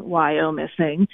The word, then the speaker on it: Wyomissing: you don't pronounce the O in Wyomissing.